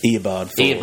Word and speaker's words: Eobard Thawne: Is it Eobard Thawne? Is that how you say it? – Eobard Thawne.